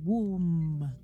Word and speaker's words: womb: Silent B. Womb.